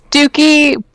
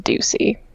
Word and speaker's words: Ducey: I mean, I would probably just pronounce it Dookie, but it is pronounced Ducey.